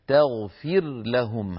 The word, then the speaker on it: تغفر لهم: تغفر لهم، ها هنا اجتمع في النطق راء ساكنة في تغفر ولام في لهم، تغفر لهم. فبالنسبة للقراء ما عدا أبو عمرو البصري فإنهم يظهرون هذه الراء عند اللام فيقولون وإن تغفر لهم، تغفر لهم يعني لا يدغمون الراء في اللام.